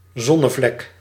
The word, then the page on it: zonnevlek: /'zɔnəvlɛk/